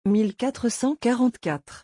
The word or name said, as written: 1444